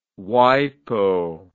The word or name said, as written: wipo